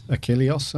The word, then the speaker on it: Achilleos: How would you say it? Achilleos, I think. – Yeah. – Yep.